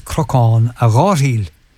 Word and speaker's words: Crocán a Ghairdhíl: That hill was known as Crocán a Ghairdhíl, the Wailing Hill. They used to pronounce the last letter L, and it sounded like Crocán a Ghairdhí, the Irish for laughing.